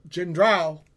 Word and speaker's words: Gendreau: Special guest, Michael Gendreau. Am I saying that right? – I you can say it that way. It gets said a lot of ways. It's pronounced gendreau.